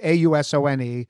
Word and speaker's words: Ausone: The most I once bought some old of their old Bordeaux, like Chateau Ausson. I probably mispronounced it, Ausone.